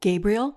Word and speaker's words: Gabriel: Hi, i I'm my name is Cheryl Gabriel, spelled like the angel,